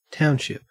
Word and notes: township: Australian English

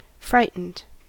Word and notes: frightened: American English

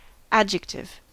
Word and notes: adjective: American English

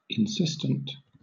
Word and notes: insistent: British English

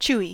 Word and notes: chewy: American English

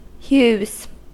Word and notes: hues: American English

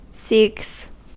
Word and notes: seeks: American English

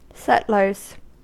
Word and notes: settlers: American English

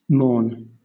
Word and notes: mourn: British English

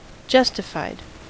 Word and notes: justified: American English

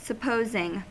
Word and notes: supposing: American English

